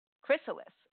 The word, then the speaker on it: Chrysalis: it's so chrysalis, how do you pronounce it chrysalis chrysalis okay all right chrysalis.